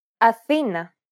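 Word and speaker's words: Athena (Αθήνα): Athena. – The accent, and thus the stress, is on the second syllable.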